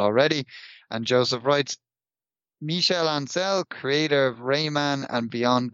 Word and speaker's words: Ancel: Rayman creator Michel Ancel, is that how we pronounce it? – Yeah. Ancel.